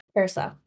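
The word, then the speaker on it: Curaçao: And I ended up using a vodka that is blue, a blue Curacao. Is that how you pronounce it? Yeah. – Curacao.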